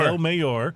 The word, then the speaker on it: El Mayor: El Mayor, uh, spelled like mayor, and it is 100% agave, and it checks out.